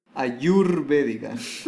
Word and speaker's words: ayurvédica: Ayurvédica. – Ayurvédica,